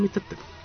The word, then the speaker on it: Mr. T: Y eso es Mr. T, con acento en live, como el té de tomar.